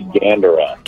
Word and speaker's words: Gandara: Zac, am I pronouncing your last name right? Is it Gandara? – It's actually Gandara.